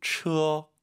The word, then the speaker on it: 车: 굳이 한국어로 대입을 시키면 츠로 시작하는 것 같아요. 츠, 츠어. 네. 처, 처, 처 하지 마시고 츠어 두 글자로 생각하시고 발음하시기 바랍니다.